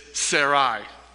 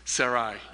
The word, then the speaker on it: Sarai: It's Sarai. Sarai, yes. Some people were asking me before service, how do you pronounce her name? I pronounce it Sarai with an I on the end.